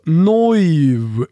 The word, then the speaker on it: Neuw: N-E-U-W, alltså Neuv blir det på tyska.